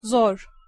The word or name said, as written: zor